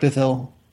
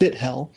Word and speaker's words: Bithell: We talked about indie developers earlier, and Mike Bithell. No matter how, even when you're saying his name right, it sounds like you're saying it wrong because that TH in the middle there. Mike Bithell,